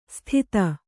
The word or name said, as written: ಸ್ಥಿತ